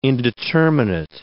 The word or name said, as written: indeterminate